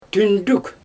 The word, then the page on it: tyndyk: Xyzyl